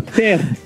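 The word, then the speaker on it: Terre: Ter, ter. En francés la E no se dice, entonces ter.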